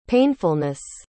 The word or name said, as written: painfulness